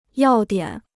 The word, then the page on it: 要点: yào diǎn